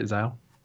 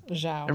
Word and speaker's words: Zhao: Zhao. – Yeah, Zhao.